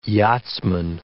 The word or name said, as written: yachtsman